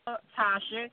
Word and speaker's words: Toshi: They can follow me on Instagram, where I'm active on that, at The Real Tasha. Real, and my name is spelled T-O-S-H-I.